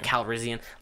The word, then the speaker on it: Calrissian: Calrissian. Is that how you say it? – Calrissian.